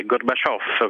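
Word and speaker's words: Gorbaciof: Bisogna fare attenzione all'accento e alla S finale, Gorbaciov, come lo direbbero a Napoli,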